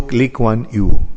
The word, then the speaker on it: Lee Kuan Yew: And the Lee Kuan Yew, did I pronounce the name all right? Lee Kuan Yew, okay.